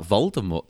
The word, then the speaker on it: Voldemort: Voldemort. That makes more sense because it looks French.